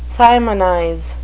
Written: simonize

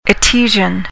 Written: etesian

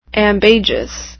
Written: ambagious

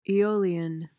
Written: eolian

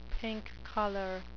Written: pink collar

